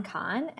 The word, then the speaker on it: Kahan: So again, my name is Susan Kahan, and I am the founder and principal of Sapphire Fundraising Specialists.